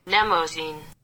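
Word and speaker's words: Mnemosyne: Okay, so the English Google Translate says it's pronounced like this. – Nemosyne. Nemosyne. Like limousine? – Yeah, it sounds like limousine.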